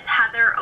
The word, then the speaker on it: Heather: Yes, Heather O'Neill, H-E-A-T-H-E-R-O-N-E-I-L-L.